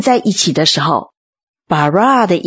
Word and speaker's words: bara: 创造的希伯来文读作 bara，虽然在旧约里 bara 这个字的含义非常的丰富，可是这个动词的主词总是神自己，这样呢就凸显了这个字的权威性。